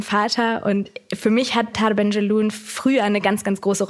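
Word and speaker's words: Tahar Ben Jelloun: Und das erste Buch, das du vorstellen wolltest, ist Sohn ihres Vaters von Taha Benjelloun. – Genau. – Ich hoffe, ich habe es richtig ausgesprochen. – Ja, total. Ja.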